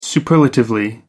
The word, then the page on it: superlatively: American English